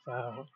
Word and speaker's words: Faro: Actually, that's not how you say it. Faro.